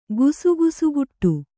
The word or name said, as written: ಗುಸುಗುಸುಗುಟ್ಟು